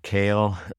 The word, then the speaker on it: Keel: I think Otmar Kael, K-E-E-L, looks like Kiel, but pronounced Kael.